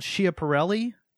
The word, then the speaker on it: Schiaparelli: Schiaparelli.